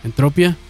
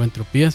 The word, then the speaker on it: Entropia: Ya para 1997 la banda publicaría su primer longplay, su primer LP llamado Entropia, o Entropía si lo quieren pronunciar en español, ya con una alineación mucho más estable, porque en los primeros años de reality sufrieron muchos cambios.